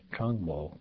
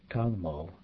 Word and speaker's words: trangmo: Others like trangmo, cold, sarpa, new, and kama, minute or star, may be pronounced without the R sound in the first syllable, trangmo, sapa, and kama.